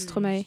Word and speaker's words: Stromae: On prononce Stromae. – Stromae parce que c'est maestro.